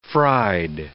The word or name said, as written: fried